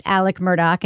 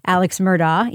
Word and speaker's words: Murdaugh: And we should mention, I think, for listeners who maybe have seen his name in headlines, it is pronounced Alex Murdaugh and not Alex Murdaugh, even though that's how it's spelled.